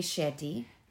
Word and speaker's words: Shetty: Shout out to Jay Shady. Is that how you say it? – Jay Shetty. – Shetty. – Shetty. Yes.